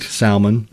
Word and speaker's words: salmon: do not pronounce the L. – Right. – Salmon, people say.